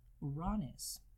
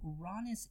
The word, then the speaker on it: Uranus: So Uranus is, and yes, I said Uranus. I know there's a lot of people that pronounce it Uranus. – Yes. – Definitely astronomers. – And Uranus. – Or Uranus and stuff like that. But when I was first learning, my teacher pronounced it Uranus, and that's just kind of how it stuck for me.